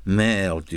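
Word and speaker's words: mère: それからフランス語では海はメール MER ラメールダダダダダダダダメール、それで母はメールって言って同じ発音で MERE だ。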